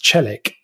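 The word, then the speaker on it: Çelik: So the cases are Battle and then also, I think it's pronounced Celik, C-E-L-I-K, which are basically about undocumented extended family members applying for the EU settlement scheme.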